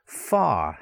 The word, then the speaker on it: far: So it sounds like K-A, car, not C-A-R, car. Yeah? Far. How far is it to the village? How far? F-A. You've got that long vowel sound. Car, far. Long vowel sound, but the R is silent.